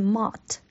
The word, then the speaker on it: Mott: 咁佢嘅英文呢其实係 MOTT， mot，係 mot。